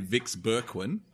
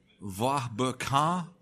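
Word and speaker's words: Vieux-Berquin: Okay, so it's, to the Aussies, it would have been Vix Berquin. And I think in French it's something like Va Berquin, something like that. – Sounds good. I'm going to go with that. – It's V-I-E-U-X hyphen B-E-R-Q-U-I-N.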